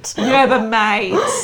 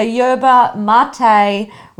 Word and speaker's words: yerba mate: I have looked up the how to pronounce it before, and I knew that one of them was pronounced not as you would. Yeah, it's not phonically. – It's not mate. Yerba mate.